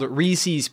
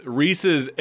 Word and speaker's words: Reese's: It's definitely Pieces. – It's definitely Reese's Pieces.